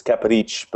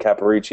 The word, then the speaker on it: Caparici: If you want to say it like my noni says it, it's Caparici, but you could say it Caparici. It would be more the North American way of saying it.